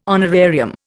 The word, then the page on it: honorarium: Indian